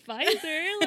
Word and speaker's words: Pfizer: And I was like, ooh, okay, I'm getting Pfizer. Like, with the silent P.